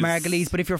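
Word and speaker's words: Margolyes: Margoyles? I'm not sure how to pronounce it. – Margoyles, I think. – Margoyles. – Thank you.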